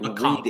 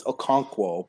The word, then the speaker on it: Okonkwo: It's if you know how to like Okonkwo. Read English, which you can't, it's Okonkwo, but okay.